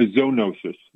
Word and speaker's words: zoonosis: You can always, if you want to sound really smart, if you see something with the prefix zoo, you can say zoo and then you pronounce it that way. So a zoonosis, that makes you sound like I study zoology instead of zoology, but anyway, Z-O-O-N-O-S-E-S.